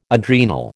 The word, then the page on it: adrenal: American English